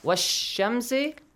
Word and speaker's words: والشمس: Wash-shamsi. Do I pronounce the lam? Do I say the lam? I skip it. So I directly join waw to sheen.